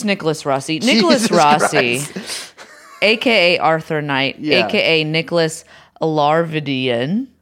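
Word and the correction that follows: Alahverdian: No, I heard a guy pronounce it earlier. Alaverdian. – Okay. Nicholas Alaverdian.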